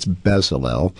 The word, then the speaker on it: Bezalel: And I'll maybe alert our listeners that Witness Lee maybe struggled a bit with the pronunciation of this word in English, but it's Bezalel, and I think now everyone will be able to realize who he's speaking about.